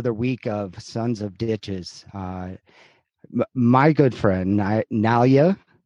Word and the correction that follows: Nyla: So it's like New York to LA. – Yes. – NYLA, Nyla.